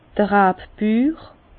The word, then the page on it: d'r Rabbür: Bas-Rhin; Herrlisheim